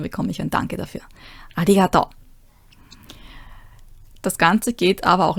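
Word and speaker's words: Arigato (ありがとう): Ah ja, Betonung. Arigato. Beim I. Arigato. Nicht arigato. Ja, nicht auf dem To hinten, sondern arigato. Da, da, da, da. Da, da, da, da. Also unten, rauf, runter.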